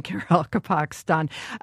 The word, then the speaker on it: Karakalpakstan: Yes, it took me about a year to get used to pronouncing Karakalpakstan.